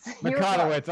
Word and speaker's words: Mikotowicz: Mikotowicz.